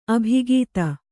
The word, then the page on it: ಅಭಿಗೀತ: abhigīta